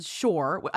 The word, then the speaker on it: Szohr: You have Moore on Shore. Again, somebody reminding you how to pronounce their last name. Jessica Shore's last name is spelled S-Z-O-H-R, which is, so she's reminding you it's pronounced like Moore.